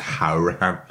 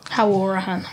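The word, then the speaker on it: Hourihane: We always have this debate on the podcast whether how you pronounce it. Apparently, it's Hourihan. Anyway, Hourihan.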